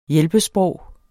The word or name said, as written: hjælpesprog